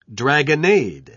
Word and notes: dragonnade: British English

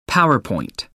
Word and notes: power point: American English

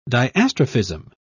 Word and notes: diastrophism: British English

